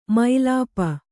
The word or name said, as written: ಮೈಲಾಪ